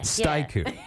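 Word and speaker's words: Styku: Okay, the Sheridan YMCA's also got the, I'm always trying to, is it Staiku? – Oh, yeah. – Staiku. – Yeah. – Am I saying that right? – Sure, I think so. – It sounds right? – Like, now that you've said that way, I think that's how it's pronounced.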